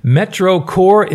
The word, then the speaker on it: MetroCorps: MetroCorps is actually how it's pronounced. MetroCorps, it's spelled M-E-T-R-O, Metro, and then Corps is spelled C-O-R-P-S. The P-S is silent.